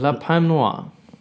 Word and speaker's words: La Palme Noire: Ähm, Hotel, wie wird es genau jetzt ausgesprochen? La Palme Noire. Richtig. Geil, richtig. Das hast du ganz toll ausgesprochen.